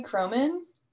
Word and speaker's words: Kroman: Yep, Emily Croman, K-R-O-M-A-N.